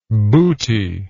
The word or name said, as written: bootyless